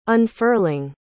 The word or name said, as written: unfurling